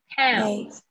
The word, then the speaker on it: pound: Pounds. – Pound. Pound. – Pound. – Pounds. – 它是 out，嗯，不要念成胖子，它是 out 的声音 pound。